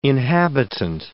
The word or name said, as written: inhabitant